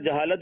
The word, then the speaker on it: جہالت: جناب بتائیے کیا درست کرنا ہے, جہالت یا جہالت, کیا درست ہے؟ – جہالت. درست تو خیر جہالت بھی نہیں ہے, جہالت نہیں ہونی چاہیے, لیکن یہاں تلفظ جو ہے وہ جہالت صحیح ہے, اور جہالت سے مراد جو ہے وہ بے علمی ہے.